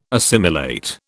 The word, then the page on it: assimilate: British English